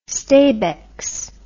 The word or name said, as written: stabex